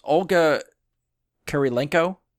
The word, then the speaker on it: Olga Kurylenko: Olga Kurylenko? – Yes, you said it right.